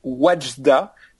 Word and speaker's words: Wadjda: Alors, c'est Wajda.